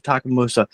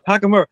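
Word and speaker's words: Takemura: I almost said Takamusa. Takamura.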